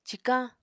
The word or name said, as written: cikañ